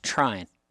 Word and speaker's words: Tryon: As somebody from Rome, Georgia, I've always heard it pronounced Trine.